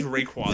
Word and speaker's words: Rayquaza: It's pronounced Rayquaza.